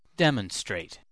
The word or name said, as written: demonstrate